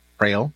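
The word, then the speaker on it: Praill: It's Daryl Prail. So Daryl, D-A-R-R-Y-L, Prail, Peter, R-A-I-L-L.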